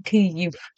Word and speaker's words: Kyiv: The most good pronunciation, Kyiv. Yes, the true pronunciation, Kyiv.